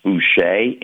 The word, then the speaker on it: Boucher: The original spelling of the name was E-R, which in France means butcher, sure. – And always pronounced Boucher.